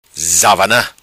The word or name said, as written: Zavana